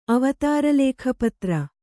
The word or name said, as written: ಅವತಾರಲೇಖಪತ್ರ